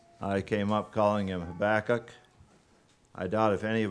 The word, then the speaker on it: Habakkuk: The Hebrew would have always emphasized the last syllable, so Habakkuk, I suppose, is how he might have pronounced it.